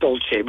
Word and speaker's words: Dolce: Well, my parents call themselves Dolce. They've Americanized it. So when I went to university, I had a teacher who told me I should be pronouncing it properly. – Yes. – So I started calling it Dolce. But I go home and everyone still calls me Joe Dolce.